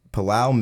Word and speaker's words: Palau: It's widely only played in like Palau and like Guam and American Samoa, but mainly Palau.